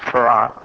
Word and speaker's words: Fard: His name is spelled F-A-R-D, but pronounced Farad.